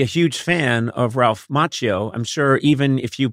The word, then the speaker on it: Macchio: How would you pronounce his name? – I remember it's M-A-C-C-H-I-O, right? – Yeah, yeah. – Macchio.